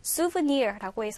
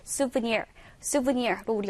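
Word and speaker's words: souvenir: 이 단어가 얘들아 조금 영어스럽진 않게 생겼죠. 실은 발음도요. souvenir라고 해서 조금 어려울 수 있어요. 듣기 평가에 종종 나오는 단어인데 우리 친구들 발음 때문에 놓친 경우가 좀 있더라고요. 같이 일단 발음은 souvenir로 우리 연습할 수 있고요.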